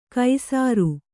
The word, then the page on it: ಕೈಸಾರು: kai sāru